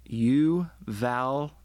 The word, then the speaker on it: Uvalde: Did I pronounce that correctly? – It's Uvalde.